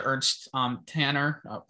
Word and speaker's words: Ernst Tanner: I think it goes to show you what patience, what having someone like Ernie Stewart to Ernst Tanner. – You had that right. – Yeah, I was like, yeah, that's the pronunciation.